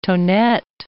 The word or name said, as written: tonette